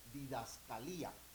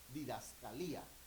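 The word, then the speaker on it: Didascalía (διδασκαλία): Esa palabra doctrinas en el griego es didascalía. ¿Cómo dije, hermanos? ¿Cómo se pronuncia? Didascalía.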